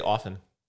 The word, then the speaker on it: often: How do you say often? – Often. – I was always taught that the T is silent.